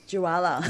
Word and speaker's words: Jwala: Joella. Yeah, whatever. – Joella. – Yeah. – Spell it for us. – J-W-A-L-A.